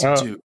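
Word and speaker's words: Duqu: Duku? – I've heard it's pronounced Duke. – Okay. Interesting. – But I always pronounced it Duku as well, but whatever.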